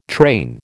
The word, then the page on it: train: American English